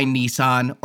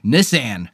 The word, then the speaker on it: Nissan: It was done by Nissan, or I'm sorry, Nissan. – Yeah. – That's how they pronounce it in this movie. I'm going to pronounce it throughout this podcast like they do in the television ads, Nissan. But throughout this whole movie, Nissan.